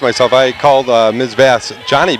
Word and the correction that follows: Jonnie: Bass. It's actually Joni, she corrected me, even though it's spelled J-O-N-N-I-E. – Yeah, J-O-N-N-I-E.